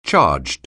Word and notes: charged: anglais

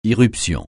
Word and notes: irruption: francais

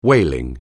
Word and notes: whaling: anglais

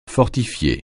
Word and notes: fortifier: francais

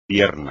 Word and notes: pierna: espagnol

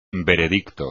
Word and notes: veredicto: espagnol

